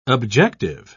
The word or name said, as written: objective